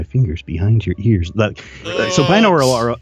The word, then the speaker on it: binaural: Binaural? Am I saying it right? – Yeah, binaural.